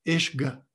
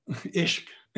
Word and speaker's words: ishq: And that's called ishq or ash- ashqa, or I should pronounce it in the original way, ishq, ishq.